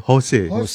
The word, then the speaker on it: Jose: Bukan, bacanya bukan Jose ya, tapi Jose Marichan.